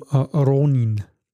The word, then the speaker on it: Ronin: Wie spricht man das? Ronin, ne? – R. Im Japanischen sind wir weiter vorne. – Okay, also dann wird er zum Ronin.